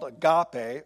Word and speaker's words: agape: The love that is being described here in 1 Corinthians is the highest type of love there is, and it's called agape, or agape, depending on how you want to pronounce it, love. I'm going to do agape. Agape.